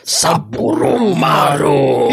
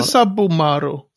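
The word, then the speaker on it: Saburomaru: Du får tre minuter om destilleriet som jag tror uttalas Saburumaru. Saburumaru! – Heter det Saburumaru? – Sa, mm, inte Sabumaru.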